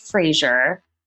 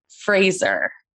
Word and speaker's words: Fraser: His name is not pronounced Fraser. It's Fraser, correct? – Wow. That's spot on. One of the first Americans that said it correctly.